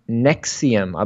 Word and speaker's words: NXIVM: was part of a sex cult known as Nexium, I believe is the pronunciation, Soph, you said. But it's spelled, in all caps, N-X-I-V-M.